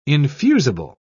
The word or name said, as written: infusible